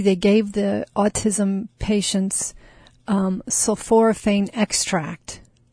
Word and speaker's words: sulforaphane: And so the sulforaphane, is that how you pronounce it? – Sulforaphane.